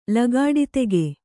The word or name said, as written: ಲಗಾಡಿತೆಗೆ